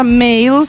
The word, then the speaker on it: Mhaol: È una band nata nel 2016, si chiamano, si pronuncia Male, come appunto maschile, si scrive M-H-A-O-L ed è una parola...